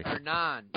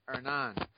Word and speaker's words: Hernan: Hernan. The H is silent. Hernan.